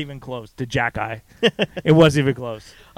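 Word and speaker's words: Xhekaj: The X-H-E-J-A-J. – Oh, yes, it's pronounced Jack-Eye. – It's Arbor Jack-Eye.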